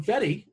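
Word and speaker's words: Fedde: Well, because you didn't pronounce his name correctly. If you would have called him Fetty, like his name, like, you know, like he's named, maybe he would have got smashed.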